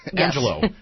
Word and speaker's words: Angelo: Angelo. Not Angela. – Yes. – I think you said Angela. – Yes. I didn't mean that. – Okay. It's Angelo.